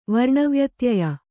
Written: ವರ್ಣವ್ಯತ್ಯಯ

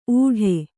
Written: ಊಡೆ

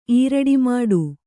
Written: ಈರಡಿಮಾಡು

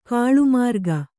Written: ಕಾಲಮುರಿಗೆ

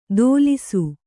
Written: ದೋಲಿಸು

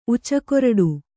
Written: ಉಚ್ಚುಕೊರಡು

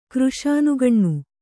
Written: ಕೃಶಾನುಗಣ್ಣು

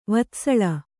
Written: ವತ್ಸಲ

